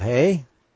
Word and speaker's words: Hey: And Vav Hey, the H-E would be Hey.